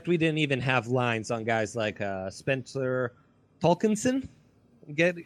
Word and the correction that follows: Torkelson: We're getting close on the pronunciation guy. Torkelson.